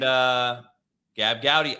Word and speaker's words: Gabb Goudy: And Gab Gowdy, I got your name right.